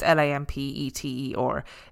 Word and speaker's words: Lampeter: I'm very sorry if I've pronounced Lampeter wrong. It's Lampeter.